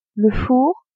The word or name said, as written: four